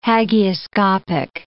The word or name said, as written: hagioscopic